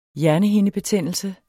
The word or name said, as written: hjernehindebetændelse